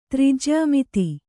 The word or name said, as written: ತ್ರಿಜ್ಯಾಮಿತಿ